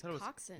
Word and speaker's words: coxswain: It's coxswain. – It's coxswain, not swing. – But it's called, it's spelt coxswain. – It's just not pronounced that at all.